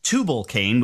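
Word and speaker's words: Tubal-cain: Tubal Cain, which is one of the descendants of Cain, if I'm saying that correctly. – Very good, yes.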